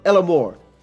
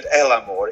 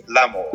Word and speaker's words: l'amour: See Through Trouve l'amour. Did I get that right? A bit. – Nearly. You brought a bit of Spanish in. You said el amor. It should just be l'amour.